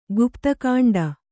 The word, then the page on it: ಗುಪ್ತಕಾಂಡ: gupta kāṇḍa